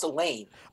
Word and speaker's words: Alain: I believe it's pronounced Alain. – I think you're right. But you never know if it's just a typo and should be Alan.